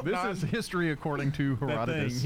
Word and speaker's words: Herodotus: So our categories today are history according to Herodotus.